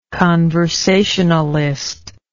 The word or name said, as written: conversationalist